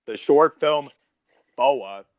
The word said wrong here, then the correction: Bao: B-A-O. – Bow. Yeah, bow. – Oh my god, it wasn't even close.